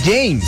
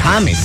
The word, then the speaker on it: James: Entonces no se dice James, se dice James. Hay que decirles. – Claro. – Correctamente, James.